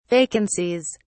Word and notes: vacancies: British English

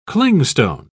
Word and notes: clingstone: British English

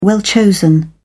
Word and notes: well-chosen: British English

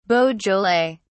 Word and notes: beaujolais: British English

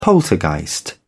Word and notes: poltergeist: British English

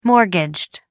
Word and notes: mortgaged: American English

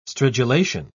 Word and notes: stridulation: British English

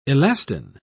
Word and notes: elastin: British English

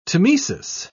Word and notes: tmesis: British English

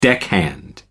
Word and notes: deckhand: American English

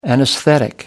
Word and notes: anesthetic: American English

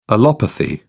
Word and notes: allopathy: British English

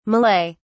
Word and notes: malay: British English

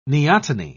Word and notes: neoteny: British English

